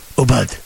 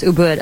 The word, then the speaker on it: Ubud: Ubud. – It's pronounced it, Ubud. – It actually is. It's pronounced Ubud. I've been there.